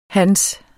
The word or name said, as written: hans